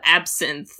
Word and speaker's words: absinthe: Absinthe.